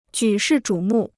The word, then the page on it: 举世瞩目: jǔ shì zhǔ mù